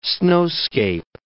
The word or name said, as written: snowscape